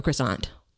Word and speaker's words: croissant: Oh, I hate it when I hate that pronunciation, but I know it's how it's supposed to be. But you know, down here we call it croissant.